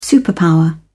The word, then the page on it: superpower: British English